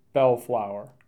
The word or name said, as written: Bellflower